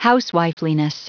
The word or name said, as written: housewifeliness